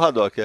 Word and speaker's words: Haddock: Que você pronuncia? – Eu falo Haddock, é. Haddock.